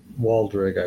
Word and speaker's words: Wadreg: Walrog or? – Waldreg, I believe. It's spelled W-A-D-R-E-G.